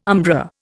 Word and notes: umbra: Indian

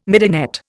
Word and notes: midinette: Indian

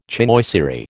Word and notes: chinoiserie: American English

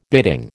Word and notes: bidding: American English

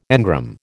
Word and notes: engram: American English

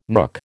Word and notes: nook: American English

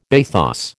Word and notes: bathos: American English

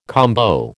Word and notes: combo: American English

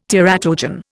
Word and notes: teratogen: Indian

